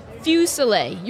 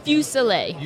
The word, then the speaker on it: Fuselier: Is that how you say your last name? – Fuselier. You were close. Fuselier.